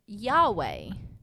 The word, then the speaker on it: Yahweh: Yahweh is how we would say it. – How we pronounce it.